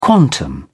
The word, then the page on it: quantum: British English